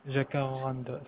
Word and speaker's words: Jacaranda: Jacaranda, c'est ça.